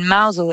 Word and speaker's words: Mousehole: And in Mousel, it used to be... – It's spelled Mousehole, for those of you keeping score at home, but it's pronounced Mousel.